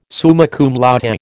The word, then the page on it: summa cum laude: American English